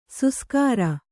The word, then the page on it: ಸುಸ್ಕಾರ: suskāra